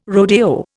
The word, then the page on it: rodeo: Indian